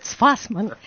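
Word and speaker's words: Tsfasman: D'ailleurs, le pari est réussi parce que on a entendu cet enthousiasme du public pour ce Tfasfan qui est si difficile à prononcer pour nous Français. – Tfasman, Alexandre Tfasman,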